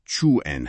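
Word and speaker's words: Qwen: کوئن که گفتی تلفظش چوئن هستش.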